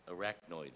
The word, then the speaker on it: arachnoid: Am I saying that right? Say it again? Arachnoid cyst. That's what it was, an arachnoid cyst.